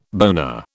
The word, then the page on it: boner: British English